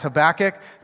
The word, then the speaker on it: Habakkuk: I just said Habakkuk. Some of you guys, no, it's Habakkuk. Well, that's fine. We figured it out. It's Habakkuk. That's what I'm going to say.